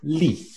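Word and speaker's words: lit: The word for bed up here, li. – Li. – Good. The frame that I'm using is I'm trying to just put the sound L in front of the vowel to produce a word.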